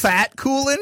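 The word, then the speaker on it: Fatkullin: Rasteem Fatkulin versus Fatkulin. – F-A-T-K-U-L-L.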